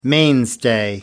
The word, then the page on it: mainstay: American English